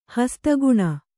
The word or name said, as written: ಹಸ್ತಗುಣ